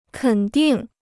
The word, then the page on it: 肯定: kěn dìng